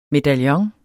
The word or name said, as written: medaljon